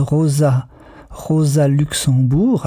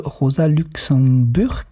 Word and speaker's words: Rosa Luxemburg: Et je vous prie de bien vouloir m'excuser de toujours prononcer le nom de Rosa Luxembourg, alors qu'il s'agit bien de Rosa Luxemburg.